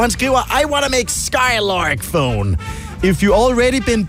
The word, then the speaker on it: SKYLRK: Og jeg er ret sikker på, at man skal udtale det Skylark. Det staves S-K-Y-L-R-K.